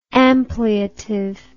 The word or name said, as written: ampliative